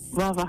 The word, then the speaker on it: Lava Hot Springs: The pronunciation of your city. – Lava Hot Springs. – People call it Lava Hot Springs and Lava Hot Springs. – Yeah, it's lava.